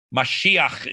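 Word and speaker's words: mashiach (משיח): Out of the word, the Hebrew word mashah, Messiah, the mashad one, mashiach is how we say it properly in Hebrew.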